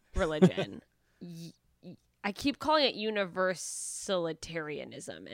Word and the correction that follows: Unitarian Universalism: and I know that's not right. – Well, it's close. It's Unitarian Universalism.